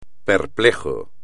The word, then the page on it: perplejo: espagnol